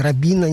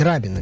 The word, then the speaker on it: Рабина: Тут вопрос о том, как ставить ударение. Площадь Рабина, нет, площадь Рабина, безусловно.